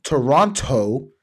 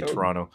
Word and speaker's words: Toronto: You said it wrong. Toronto. – Toronto. That's how Americans say Toronto. They say the second T. We don't say that. We say Toronto. – No, that's T silent.